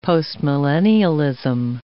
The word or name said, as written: postmillennialism